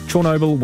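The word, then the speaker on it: Chornobyl: I'm going to have to retrain myself to do the right pronunciation now. Chernobyl. Ukrainian pronunciation. Chernobyl was tops.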